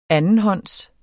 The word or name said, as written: andenhånds